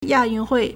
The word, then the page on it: 亚运会: yà yùn Huì